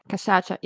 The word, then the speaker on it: cachaça: Which is brandy from cane sugar and is much popularly known as cachaça. Is that how you say it? – Yeah, cachaça, yeah.